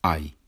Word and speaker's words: hay: Hoy vamos a hablar de una palabra muy corta, la palabra I, H-A-Y, significa en inglés there is, there are, en Spanish, en español, siempre igual, always the same, hay, H-A-Y, pero la H, H, no se pronuncia en español, hay.